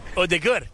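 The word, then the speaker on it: Ødegaard: What about Odegaard? – Odegaard. Yeah.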